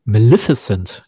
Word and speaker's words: Maleficent: Äh, Maleficent ist, glaube ich, die richtige genau.